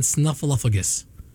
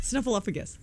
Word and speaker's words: Snuffleupagus: Am I saying it wrong? – Snuffleupagus. – Snuffleupagus. That's what I'm saying. – Snuffleupagus. – I did not say Snuffleupagus.